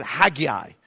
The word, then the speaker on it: Haggai: Okay, turn in your Bible to this little book called Haggai. Or some people pronounce it Haggai.